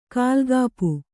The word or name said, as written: ಕಾಲ್ಗಾಪು